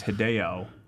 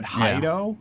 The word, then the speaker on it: Hideo: I thought it said Hido. Yeah. Like, I didn't know how to pronounce it. I thought it was the word hide, and I was like, am I supposed to hide?